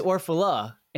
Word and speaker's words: Orfalea: In fact, if you had pronounced my name correctly, yes, which is Orfala, and anybody had typed that in, they wouldn't be able to find me.